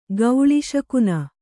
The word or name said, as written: ಗೌಳಿಶಕುನ